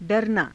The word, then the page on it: _daranaa: Hindi